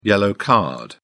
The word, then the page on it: yellow card: English; British English